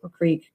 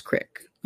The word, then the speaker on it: creek: We did a little live yesterday from the creek, but we also, creek, I've heard it both ways. – You are from Idaho. It's Crick. I mean, Crick. We all know it, but it's spelled Creek. So, yeah, it's a little rednecky around here, especially in Ledore, Idaho.